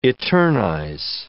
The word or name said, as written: eternize